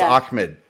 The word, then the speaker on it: Ahmed: I think it's pronounced Ahmed. That's how I've heard it every time. It's spelled A-H-M-E-D. And people say Ahmed.